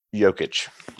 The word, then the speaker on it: Jokic: He's got a soft J. – Jokic. There you go.